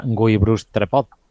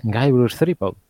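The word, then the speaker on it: Guybrush Threepwood: No era Guy Bruce Trepod, era Guy Bruce Threepod.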